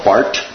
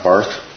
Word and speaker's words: Barth: But neo-orthodoxy, names such as Karl Barth, by the way, that's pronounced Bart, not Barth, Paul Tillich, Barth was Swiss, Tillich was German, and others.